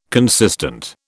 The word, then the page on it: consistent: British English